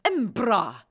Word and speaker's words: Edinburgh: Sie lebte, während sie die ersten Seiten des ersten Harry Potter Buches geschrieben hatte, in Edinburgh in Schottland. Oder wie die Schotten es aussprechen, Embra.